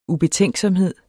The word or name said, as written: ubetænksomhed